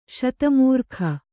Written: ಶತಮೂರ್ಖ

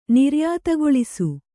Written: ನಿರ್ಯಾತಗೊಳಿಸು

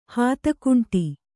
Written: ಹಾತಕುಂಟಿ

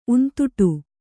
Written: ಉಂತುಟು

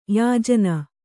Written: ಯಾಜನ

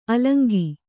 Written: ಅಲಂಗ

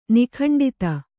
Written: ನಿಖಂಡಿತ

